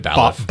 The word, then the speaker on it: Baliff: David Baliff. – Baliff. – Yeah. And yeah, just think it should. You want to say bailiff because like a court I do.